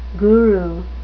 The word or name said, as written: guru